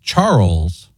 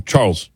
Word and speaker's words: Charles: It's a bit of a debate because Charles does not have an up and down. – Mm-hmm. – But you could pronounce it Charles, and now you've had a stress on the first part of it, and you developed it into two syllables. – Mm. – But historically, one syllable. – I would think one syllable, Charles.